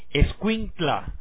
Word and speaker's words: Escuintla: Me alegro de verdad estar con Sofía Del Cid, de Finca El Barretal, Escuintla, ¿verdad? ¿Lo dije bien? – Sí, correcto.